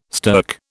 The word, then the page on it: stirk: British English